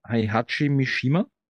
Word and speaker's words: Heihachi Mishima: Und nochmal zurückzukommen zu Bastis Thema, glaube ich, die PS2-Version war, ich werde den Namen fix falsch aussprechen, Heihachi Mishima.